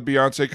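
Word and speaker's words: Beyoncé: Um, well, I went to the uh, the Beyonce concert movie with my friends at the AMC at City Walk over the weekend. – No, that uh, rhymes with fiance. Am I wrong? – No, you're not.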